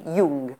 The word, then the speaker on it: Jung: Jung se escribe con J y se pronuncia así, Jung.